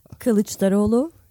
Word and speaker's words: Kılıçdaroğlu: Kılıçdaroğlu.